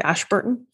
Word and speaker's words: Ashburton: Ashburton.